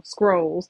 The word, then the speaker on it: Skrulls: I've been saying that wrong for a while. Skrulls.